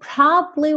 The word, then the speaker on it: probably: Essa palavra probably, muitas vezes a segunda sílaba, de, né, sílaba muitas vezes a segunda sílaba dela some. Então você não escuta probably, probably. Fica probably, probably.